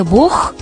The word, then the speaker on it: Бог: Мы говорим «вот тебе Бог», а вот порог. В слове «бог» мы произносим именно этот звук.